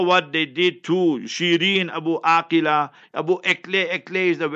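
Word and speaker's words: Abu Akleh: So Abu Aqila is the proper pronunciation.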